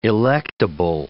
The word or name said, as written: electable